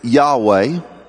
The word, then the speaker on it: Yahweh: But God does have a name in the Hebrew tradition, pronounced Yahweh, exemplified by the letters YHWH.